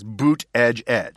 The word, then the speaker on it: Buttigieg: I know that Donald Trump said that this gave this primer about how to pronounce it. It's boot edge edge.